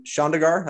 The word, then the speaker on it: Chandigarh: So you were born and raised in Chandigarh, I pronounced that properly, India.